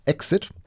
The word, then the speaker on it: XIT: Even as that British quartet was laying the foundation, XIT, pronounced Exit, was singing about the Indigenous experience on its 1972 album, Plight of the Red Man.